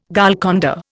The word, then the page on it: Golconda: Indian